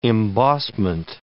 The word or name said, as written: embossment